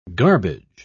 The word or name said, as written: garbage